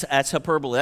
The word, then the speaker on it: hyperbole: The word here for more excellent way is hyperbole, H-Y-P-E-R-B-O-L-E. It's a hard word to pronounce, but I got it down, hyperbole. And it means to exaggerate beyond belief, to say something that nobody believes is so extreme.